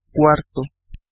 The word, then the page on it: cuarto: /ˈkwar.to/